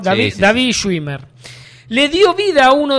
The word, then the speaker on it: David Schwimmer: El nombre real es David Schwimmer. – Sí, sí. – Así se pronuncia.